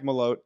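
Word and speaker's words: Malott: One of my favorite Canadian prospects now, Mike Mallett. I'll say his name correctly, Mike Malote.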